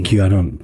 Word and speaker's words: QAnon: QAnon. – ¿Cómo? QAnon. – Q de letra Q en inglés y anon de anónimo, de la Q anónima.